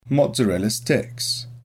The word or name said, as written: mozzarella sticks